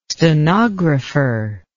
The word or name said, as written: stenographer